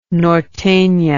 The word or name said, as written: nortena